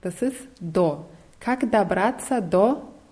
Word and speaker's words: до: Es wird DO geschrieben. Dieses O wird aber nicht so stark ausgesprochen. Es ist so ein bisschen geschluckt. Как добраться до почты, also ein bisschen wie A wird es ausgesprochen.